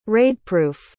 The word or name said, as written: raidproof